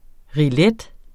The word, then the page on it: rillette: ʁiˈlεd; ʁiˈlεdə; ʁiˈjεd